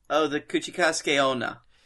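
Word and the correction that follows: Kuchisake-onna: Kuchisake Onna.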